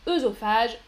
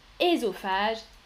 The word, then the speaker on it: œsophage: Beaucoup de Français disent œsophage, œstrogène, mais la prononciation traditionnelle, c'est ésophage estrogène.